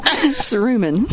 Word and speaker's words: cerumen: Cerumen. – Cerumen removal, which is a big deal, very much can help you so much with your hearing to have that removed.